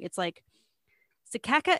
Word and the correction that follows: cicada: it's that bug that's loud and makes a lot of noise. – Cicada.